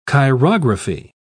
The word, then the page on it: chirography: British English